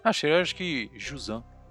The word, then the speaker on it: Jusant: E o U não tem acento, não tem nada, então você vai fechar um pouco, vai ficar Jusan, só isso.